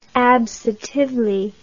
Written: absotively

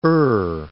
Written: Ur

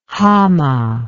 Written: hama

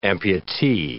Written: amputee

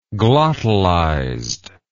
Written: glottalized

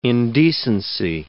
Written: indecency

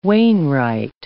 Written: wainwright

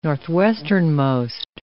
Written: northwesternmost

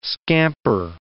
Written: scamper

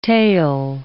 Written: tael

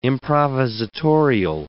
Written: improvisatorial

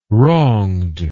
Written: wronged

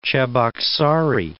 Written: Cheboksary

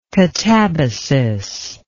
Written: katabasis

